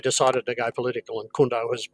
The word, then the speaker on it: KHUNTO: And then we have, it's spelt as an acronym, K-H-U-N-T-O. – Kunto.